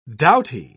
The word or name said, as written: doughty